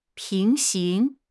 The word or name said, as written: 平行